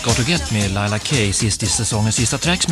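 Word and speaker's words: Leila K: Men hade han lyssnat på hur Kai uttalade hennes namn innan låten kom igång, då kanske han också hade sagt korrekt Laila Kay.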